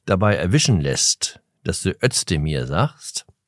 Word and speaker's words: Özdemir: Ich dachte aber, es heißt Özdemir. Mit summenden S.